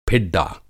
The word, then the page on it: फिड्डा: Hindi